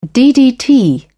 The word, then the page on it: ddt: British English